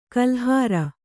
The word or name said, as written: ಕಾಲುಹಾರು